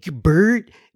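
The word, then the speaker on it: Birte: The I is pronounced as it is, as you would in give. So Bert, give.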